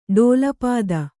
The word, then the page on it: ಡೋಲಪಾದ: ḍōlapāda